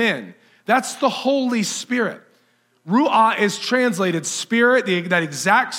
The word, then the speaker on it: ruah: In verse 2, where it says the Spirit of God, that word Spirit is the Hebrew word ruah. It's R-U-A-H, ruah. And I'm probably not pronouncing that exactly how the theologians would.